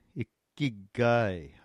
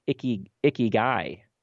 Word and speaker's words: ikigai: I was on tour, and a guy comes up afterwards, and he says, "You know, I speak Japanese, and it's actually pronounced ikigai. And I was like, yeah, and I speak English, and I'm not going to say icky guy because that just doesn't sound right.